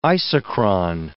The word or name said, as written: isochron